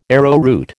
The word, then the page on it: arrowroot: American English